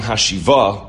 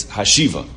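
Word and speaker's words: Hashiva (השיבה): The emphasis is not on Hashiva, it's Hashiva. That's just the correct way to pronounce the word when davening.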